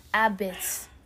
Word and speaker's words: apizza: Also, this is locally known as apizza, pronounced abits.